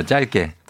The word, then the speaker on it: 짧게: 아, 짧게가 맞는 발음인가요? 그럼요, 짧게. 또한수 배우네요.